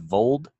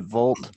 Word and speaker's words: Vold: His name's Colby Vold, right? V-O-L-D, just for the guy himself, because I accidentally mispronounced it, Volt, for our online content, but that might be in the blooper reel.